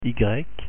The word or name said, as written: y